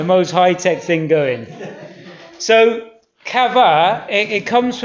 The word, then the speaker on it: qavah: It's chava or chava. Q-A-V-A-H. Yeah, chava.